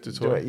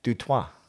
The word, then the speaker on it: Du Toit: It's not Dutch. It's actually French. It's meant to be pronounced Du Toit." – Right. – But the South Africans, a lot of them pronounce it Du Toit, but it's Du Toit.